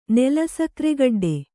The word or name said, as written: ನೆಲಸಕ್ರೆಗಡ್ಡೆ